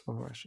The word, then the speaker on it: Omorashi: How do you pronounce this? Omorashi? – Yes. Omorashi.